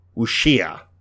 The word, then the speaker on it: wuxia: I'm going to say wuxia. – Good. – Okay. – We'll take it. – It's wuxia. One of those W-U-X-I-A things.